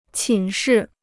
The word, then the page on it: 寝室: qǐn shì